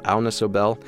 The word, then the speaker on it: Agnes Obel: She's a singer from Denmark, so I think actually you would pronounce her name Agnes Obel. Agnes Obel.